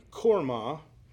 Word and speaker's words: Hormah: Therefore, it gained the name of Hormah. And that's really pronounced in the Israelite. If we pronounced it in the Hebrew, it would be Kormah.